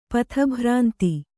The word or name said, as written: ಪಥಭ್ರಾಂತ